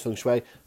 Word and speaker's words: Feng Shui: Uh, feng Shui, or spelt Feng Shui, but pronounced Feng Shui, as far as I'm aware,